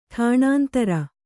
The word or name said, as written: ಟಾಣಾಂತರ